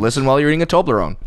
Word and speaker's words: Toblerone: Toblerone. – Don't say it right. Go listen to it. – Right? – Yeah, there you go. Toblerone.